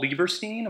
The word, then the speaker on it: Lieberstein: Paul Lieberstein, am I saying that correctly? – Yeah.